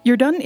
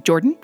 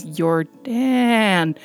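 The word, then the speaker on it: Jordaan: Yerdun is a Dutch name. Actually, I've had people pronounce it as just Jordan, as in the Canadian or American version of it. I've also had people pronounce it as Yerdun, really emphasizing the double A there.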